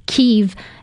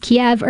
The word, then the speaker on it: Kyiv: Kyiv. Now, I've heard it pronounced Kiev or Kyiv. Please don't ding me on that. I've always said Kiev, so I'm just going to stick with that.